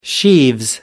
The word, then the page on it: sheaves: American English